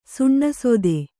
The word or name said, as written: ಸುಣ್ಣಸೋತೆ